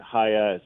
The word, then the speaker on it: Chaya: Well, I'm a big fan of, I think, Chaya, I think she pronounces it Haya. It's a Hebrew name.